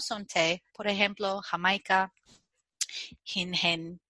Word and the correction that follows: jengibre: jengibre y cosas así. Uh Jengibre. – No sé por qué yo quiero añadir esta sílaba extra al principio.